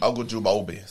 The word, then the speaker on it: Ogunjobi: They also went and got Larry, I'm going to butcher his name again, Ogunjobi. Yeah, I said right. Ogunjobi, something like that. Sounds good, right? – Yeah, sounds good.